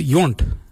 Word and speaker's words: yoornd: Mirda is spelled M-I-R-D-A. The next colour is yunt. Yunt is yellow. Yunt. Yunt is spelled Y-O-O-R-N-D.